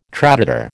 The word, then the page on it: traditor: American English